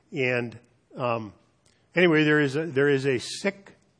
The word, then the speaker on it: Sikh: You may be familiar with the Sikh religion, and for manners, we often pronounce it Sikh. They say Sikh, and Sikhism comes out of India.